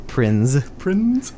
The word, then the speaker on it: Prinze: Prinze accent on the Z.